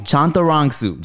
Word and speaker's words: Chantarangsu: Yes, just look at it. Look how it's spelled. It's just like that. And but all the as are Oz. – Okay. Chantarangsu. – Chantarangsu.